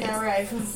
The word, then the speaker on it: Matt Rife: Matt Rife.